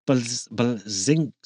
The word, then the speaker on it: Bleszinski: One of the fans is none other than the original Gears of War designer, Cliff Bleszinski. Yeah, that's how you pronounce his name. At least that's how I do it.